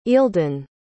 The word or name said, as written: ealdun